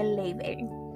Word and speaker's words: Laufey: Se escribe Laufey, pero se dice Leibei.